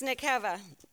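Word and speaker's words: nekeva (נקבה): The female in the Hebrew in Genesis means nekeva. It's pronounced nekeva.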